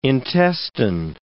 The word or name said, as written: intestine